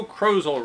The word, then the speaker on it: Crozel: Saturday, August 14th, by Joe Crozel, rhymes with puzzle.